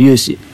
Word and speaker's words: 優心: 彼も今日はホームラン打ってるってことで、雄志ね、そう、勇士。優しい心で勇士って読むんですね。